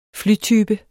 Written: flytype

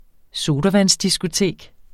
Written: sodavandsdiskotek

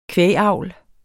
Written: kvægavl

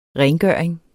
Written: rengøring

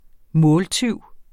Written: måltyv

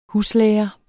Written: huslærer

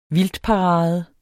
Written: vildtparade

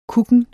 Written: kukken

